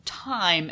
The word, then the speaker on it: Thyme: And Thyme is spelled T-H-Y-M-E. – Yes. – Right. – As opposed to T-I-M-E. – T-I-M-E. – Where there is no time, but there is plenty of time.